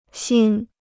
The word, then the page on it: 心: xīn